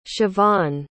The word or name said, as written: shavon